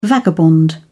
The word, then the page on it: vagabond: British English